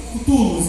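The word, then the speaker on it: Cthulhu: É, é CTU. Cutulus é porque se ser é mudo. Cutulos, isso.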